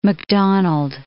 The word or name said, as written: Macdonald